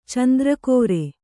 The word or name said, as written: ಚಂದ್ರಕೋರೆ